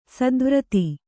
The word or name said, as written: ಸ್ಥಾವರತೆ